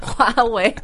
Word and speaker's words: Huawei: it's called Huawei, and it's just like... – Amazing. – It's amazing. Huawei. Huawei is the best company in the world. – 对，我听过好多美国人说Huawei。 – Huawei. – 这不难发，这个 H 把它发出声音就好。 – Well, it's just because like, we don't have any words that are spelled H-U-A.